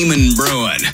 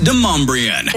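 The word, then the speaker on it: Demonbreun: Damon Bruin. It's pronounced DeMombrian.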